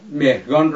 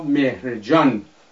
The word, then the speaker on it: مهرگان: عرب ها چون صدای گ ندارند، مهرگان را مهرجان تلفظ می کنند.